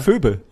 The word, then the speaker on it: Phoebe: Wie wird so ein Name ausgesprochen? – Phoebe Bridges. – Phoebe? Ist das Phoebe ausgesprochen? – Ja. – Echt? – Ja. Phoebe. Ja.